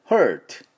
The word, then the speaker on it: hurt: チュース舌をちょっと後ろに引きながら、ちょっと潰し潰したような音でチュース、この音で Hurt Hurt というふうに言います。